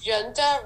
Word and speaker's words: Giunta: No one, because it's spelled Giunta, and no one gets, because it's Italian, the G is like a J. – I figured it was Italian because of the I-U-N, but yeah.